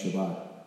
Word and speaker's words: Shabbat: I have a rabbi friend, and I was telling others that in Jewish tradition, on the, we call it the Sabbath, but in Hebrew it's pronounced Shabbat.